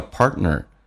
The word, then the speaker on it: partner: Your 说成 your，后面一个单词叫 partner，有的人听得不是很习惯，在美式发音。当两个辅音在一起，其实可以发一个，也就是 T 跟 N 组合，T 可以不发音，气流阻塞一下就行了，叫 partner，这个指的是另一半。